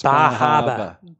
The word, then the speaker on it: Bar Harbor: It's pronounced Bahaba. – Bahaba.